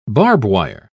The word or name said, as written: barbwire